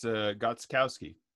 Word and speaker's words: Gostkowski: By the way, how do you pronounce the kicker's name? – I looked it up earlier. It's Gostkowski. – Very good, Gostkowski.